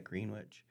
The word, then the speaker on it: Greenwich: And even the little town I live in, Greenwich, New York, they pronounce it like that, Greenwich, wasn't called Greenwich.